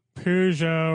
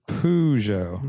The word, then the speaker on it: Peugeot: I'm just glad you pronounce it Peugeot. Mm-hmm. You like emphasize the poo.